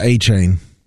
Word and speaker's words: Etienne: I guess he's going by Etienne? He's going by Etienne now. – Yeah, Etienne. – Etienne, the Haitian pronunciation.